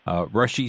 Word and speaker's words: Rusche: I believe it's pronounced Rush, R-U-S-C-H-E, Rushy Street. And again, that's 425 Rush Street, R-U-S-C-H-E Street, and that's Creve Coeur, Illinois.